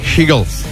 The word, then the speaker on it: Hegel: Alors, faut bien prononcer Hegel. Parce que si tu dis Eagle, Aïtchérien, j'ai l'impression que tu parles à un mec qui parle fort.